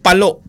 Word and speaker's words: Palo: Ano ba ang talagang tamang pagbigkas? Palo o palo? – Palo. Leyte.